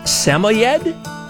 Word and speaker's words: Samoyed: I think the correct way to pronounce the breed is Samoyed. There's three or four different ways to say that.